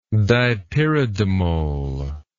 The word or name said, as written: dipyridamole